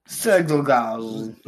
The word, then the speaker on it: Czolgosz: Leon Sergelagos. McKinley dies 8 years. I have no idea how to spell that or say that. It's Leon Czolgosz. – I should try, but I'm Sergelagos.